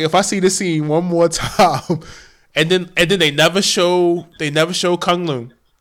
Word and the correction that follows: Kung Lao: Am I saying it right? Kung Lao.